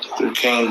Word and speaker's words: Duquesne: You pronounce it like this. Duquesne, this is on his basketball team.